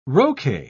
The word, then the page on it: roquet: British English